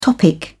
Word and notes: topic: British English

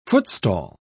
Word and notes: footstall: British English

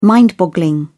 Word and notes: mind boggling: British English

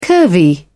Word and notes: curvy: British English

